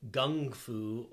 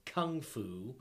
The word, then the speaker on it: gung fu: In China, you would know of as wushu or gung fu, or the way Americans say, kung fu.